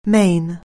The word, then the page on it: main: anglais